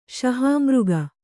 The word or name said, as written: ಶಹಾಮೃಗ